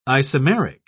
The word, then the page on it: isomeric: British English